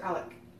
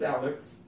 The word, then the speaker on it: Alex: Is it Alex or Alec? – That doesn't matter anymore. – It's Alex. – Is it? – Yeah, it's Alex Trebek.